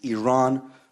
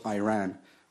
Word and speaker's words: Iran: First of all, you say you support the Iranian people, but you're pronouncing our country's name wrong. Our country is named Iran, not Iran.